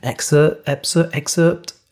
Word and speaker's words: excerpt: This episode is a little excerpt. I sometimes overpronounce that and over-enunciate because of the PT at the end. Excerpt.